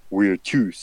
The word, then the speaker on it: virtus: virtus. So it's spelled like virtue, but it's pronounced a little bit differently.